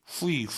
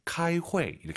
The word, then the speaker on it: 开会: 후이, 후이 하지 마세요. UI 사이에 에 넣으셔야죠. 카이회 이렇게 발음하시고요.